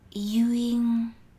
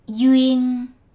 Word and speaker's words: duyên: you will need to know the concept of duyên. First, let's get the pronunciation right. Duyên. Duyên, ying, Letter D is pronounced as Y, and um, the final N is pronounced as a NG.